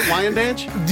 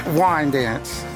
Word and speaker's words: Wyandanch: Did I get it right, Wyandanch? – You kind of didn't get it right. – Okay, well then how do I say it? – Wyandanch.